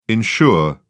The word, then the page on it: insure: British English